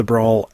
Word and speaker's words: branle: It's the Brawl X, or Brawl 10, and the Brawl de Bourgogne. Breton Brawls there. It's spelled B-R-A-N-L-E, by the way.